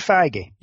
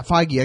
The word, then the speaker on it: Feige: Kevin Feige. – Yeah, Feige.